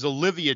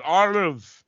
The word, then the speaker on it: Olivia: And then the youngest child is Olivia Jarrett, and Andre is always mispronouncing her name as Olive.